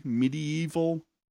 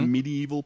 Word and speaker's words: Medieval: It's four syllables. – Technically, yes. – Okay. – There's eight letters, yeah. – Four vowels, four consonants, and they make four syllables. Yes. Medieval. – Not medieval.